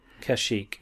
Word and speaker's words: Kashyyyk: Kashyyyk? – Kashyyyk, yeah.